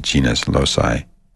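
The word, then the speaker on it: genius loci: And having done that, you tune into the spirit of the place, or what some people call the genus loci.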